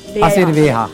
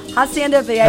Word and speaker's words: Hacienda Vieja: Hacienda Vieja. – Hacienda Vieja.